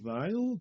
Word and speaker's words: Weil: Weil.